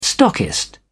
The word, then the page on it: stockist: British English